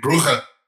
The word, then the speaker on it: Brugge: Or we could pronounce it like the Dutch, which would be Brugge, which is awful.